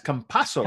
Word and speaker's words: Campazzo: We got a correct pronunciation. People have been telling me all this, these different pronunciations. It's the S, Campazzo.